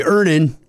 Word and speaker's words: Earnin: I'm going to pronounce this properly. Earnin. – Earnin. – E-A-R-N-I-N is pronounced Earnin. I don't know how else you would pronounce it, but they really want it clear that that's how to pronounce it.